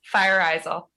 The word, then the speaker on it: Fajrazel: No, we pronounce her name Fajrazel. – Yeah. You're working with director Diana Fajrazel. – No, eyes, like eyes, fire as in fire, eyes as in I see, and L, Fajrazel.